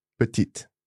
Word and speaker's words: petite: Petite. Now, I'm sure I've heard petite. – Again, some regions might overpronounce, in my opinion, okay. – pronounce a final E, but other than that, it's just the T that's pronounced here. Yeah. So petit, petite.